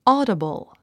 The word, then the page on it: audible: ɔ́ːdəbl; オーダブル